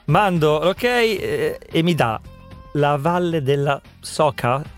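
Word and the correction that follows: Soča: Legga bene, c'è la C con quella, con quella roba sopra. – Socia, Socia.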